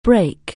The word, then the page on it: break: anglais